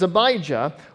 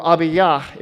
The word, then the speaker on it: Abijah: His mother's name was Abijah, or Abiyah is actually the actual pronunciation, the daughter of Zechariah.